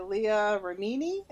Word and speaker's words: Remini: So we read Troublemaker by Leah Remini.